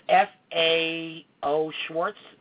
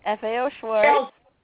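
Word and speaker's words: FAO Schwarz: And I'm going to say it right. It was F A O Schwartz. Am I right? – Yes, F A O Schwartz.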